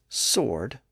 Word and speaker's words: sword: Think about the word sword. Here we have SW followed by O, and in that situation, the W sound was lost in the middle. And today, the standard pronunciation is sword without the W sound.